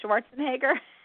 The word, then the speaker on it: Schwarzenegger: Schwarzenegger. – Yeah, well, he was very career conscious. Schwarzenegger.